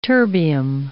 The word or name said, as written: terbium